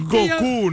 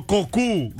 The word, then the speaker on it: Goku: Es Goku, no Goku. – ¿Cómo que no es Goku? Es la versión japonesa la que se dice. Lo dije bien, es Goku.